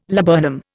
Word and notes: laburnum: Indian

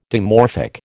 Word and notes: dimorphic: American English

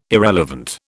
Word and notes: irrelevant: British English

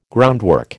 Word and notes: groundwork: American English

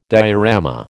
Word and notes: diorama: American English

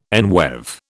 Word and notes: enweave: British English

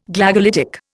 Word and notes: Glagolitic: Indian

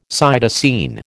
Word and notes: cytosine: American English

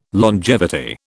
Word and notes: longevity: British English

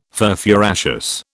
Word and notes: furfuraceous: British English

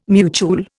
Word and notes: mutuel: Indian

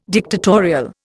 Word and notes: dictatorial: Indian

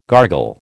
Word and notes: gargle: American English

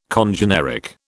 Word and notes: congeneric: British English

